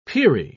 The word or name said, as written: peri